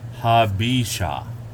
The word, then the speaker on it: Habesha: Habesha. – Okay, so we're getting the correct pronunciation here.